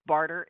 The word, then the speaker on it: Barter: Barter is spelled B-A-R-T-E-R. Sometimes it sounds like a D, but it's a T.